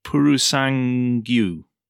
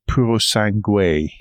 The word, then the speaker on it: Purosangue: I have been unable to find a pronunciation of the name, which is worrying me because I think it I think it is Purusangyu. No, I saw it spelt out somewhere, and I it was Purosangue.